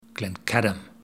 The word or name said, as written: Glencadam